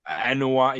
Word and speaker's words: Anoa'i: How do you, is it, how do you say their last name? Anoa'i? – Anoa'i, yeah. Yeah, that's close to, yeah, Anoa'i.